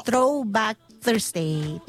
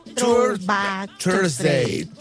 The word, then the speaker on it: Throwback Thursday: Oiga, sí es día de Throwback Thursday. ¿Cómo se dice? – Algo por el estilo. – A ver. – Thursday. – ¿Cómo? – Throwback Thursday. – Throwback Thursday. Thursday.